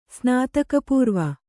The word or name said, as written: ಸ್ನಾತಕಪೂರ್ವ